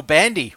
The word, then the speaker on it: Bandy: His name rhymes with yours. And that's Handy Michael Bandy.